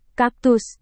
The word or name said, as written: kaktus